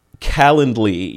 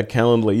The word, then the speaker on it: Calendly: And Calendly, right? Is that how I pronounce it? How do you pronounce that app? – Calendly. Yeah, Calendly.